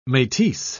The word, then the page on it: metis: British English